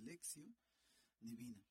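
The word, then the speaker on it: lectio divina: Lectio se escribiría divina, pero son palabras en latín y la T siempre se pronuncia como S, lección divina.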